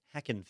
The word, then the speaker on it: Hackin': And you can't pronounce the G. That ruins everything, right? – Okay. – It's the Wild West Hackin' Fest.